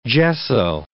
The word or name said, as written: gesso